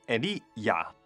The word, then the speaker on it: Elia: 厄利亚呢，是旧约里面一位的先知，他的名字叫 Elia，如果用希伯来文这样发音， Eli 即是天主， Ya 即是亚威，全个名字的意思呢，即是说我的天主呢就是亚威。